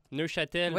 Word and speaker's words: Neufchâtel: Hier a eu lieu l'opération policière dans le secteur de Neufchâtel. Je ne sais pas si je le dis bien. Neufchâtel. – Oui.